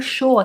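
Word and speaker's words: chaud: Então o show aqui, ó, C H A U D eu pronuncio show, show, show, show, mas se escreve a, é, S-H-A-U-D, né?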